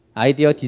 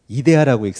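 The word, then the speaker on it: idea: 아이디어 디자인, 굉장히 좋은 디자인에 대한 생각 뭐 이런 의미이시죠. 이 단어를 다르게 읽을 수도 있어요. 이 단어를 아이디어라고 읽을 수도 있지만 어떤 사람들은 이 단어를 굳이 이데아라고 읽습니다.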